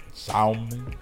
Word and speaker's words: salmon: I saw a meme that said, if you pronounce the L in salmon, we're breaking up.